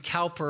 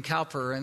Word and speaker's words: Cowper: There was a poet who was named William Cowper, or William Kuyper, depending on how you pronounce it, from England.